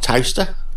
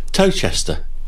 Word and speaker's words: Towcester: Towcester? Not a Towcester, if you're from London. Towcester, in the Midlands.